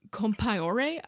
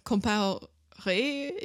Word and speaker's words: Compaoré: So then this other guy, Blaise Compaoré, I think is how you say it. – Okay. – Compaoré, if he's French, which I guess he probably is, who was a like dear friend, like right-hand guy of Sikora, was then sort of secretly plotting a coup.